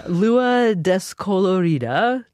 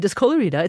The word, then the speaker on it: Lua Descolorida: This is you actually singing Lua Descolorida. Did I say that right? Oh, I probably did. – Yeah, that's right. – Okay, Descolorida.